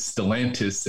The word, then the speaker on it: Stellantis: So fast forward, you know, I went to college and when I graduated, worked for Chrysler, now is Stellantis, if I'm saying that right.